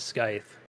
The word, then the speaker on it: scythe: So, how do you say that weapon? Is it a skith or whatever? You know, probably one of them farming fucking tools or whatever. – It's so, a Grim Reaper weapon? – Yeah, it's like a Grim Reaper, yeah. But I think it's more of a one-handed scythe. That's how you say it.